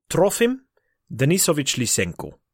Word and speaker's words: Trofim Denisovich Lysenko: So, Trofim Denisovich Lysenko.